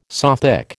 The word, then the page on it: Sothic: American English